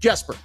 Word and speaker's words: Jesper: So, Jesper, sorry, Hard J. We clarified with him. Hard J, Jesper Bratt.